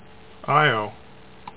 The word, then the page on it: Io: EYE oh; EE oh